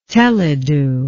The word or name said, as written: teledu